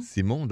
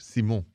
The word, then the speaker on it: Simon: Simon, that's my name in French, Simon.